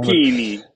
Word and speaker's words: Tacchini: Und ich weiß jetzt nicht, ob das bei Sergio, ich weiß gar nicht, ob es Tacchini oder Tacchini ausgesprochen Tacchini.